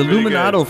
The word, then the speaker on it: Luminato: And yeah, the Luminato Festival, we've got to talk about. If I mispronounce it, some people think I'm talking about the Illuminati Festival.